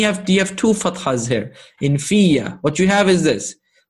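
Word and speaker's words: fiya (فِيَّ): In reality, it's pronounced and written as this, as with a shadda and a fatha, fiya, like that.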